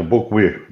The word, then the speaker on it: Bocuhy: Correto, Bocuí, da forma mais simples possível.